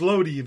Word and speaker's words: Lodi: Now, it should be pronounced Lodi if you lived in Italy, and there was a famous Napoleonic battle there,